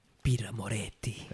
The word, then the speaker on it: Birra Moretti: Birra Moretti.